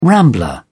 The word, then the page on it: rambler: British English